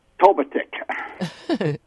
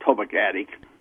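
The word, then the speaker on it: Totogatic: Tobatic. Most everybody pronounces like you did, Tobagatic, but it's Tobatic.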